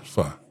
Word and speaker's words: pho: P-H-O. – It's spelled pho, but I'm Vietnamese. It's pronounced pho.